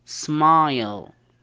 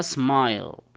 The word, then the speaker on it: smile: مثال عليه smile، ما تنطقها smil، تنطقها smile.